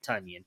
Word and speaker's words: Tonyan: Because I mean, the fact that they only have Davante Adams as a receiver on this team, and Tonyan, or I'm sorry, he pronounces it Tonyan, as Tonyan has still not done anything this year, I think speaks to you got to get rid of him.